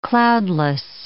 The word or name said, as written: cloudless